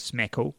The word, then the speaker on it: SMCAKL: And Social Media Club Auckland, they've done a hashtag, SMCAKL, which they say is smackle with a silent C, obviously.